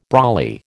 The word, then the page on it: brolly: American English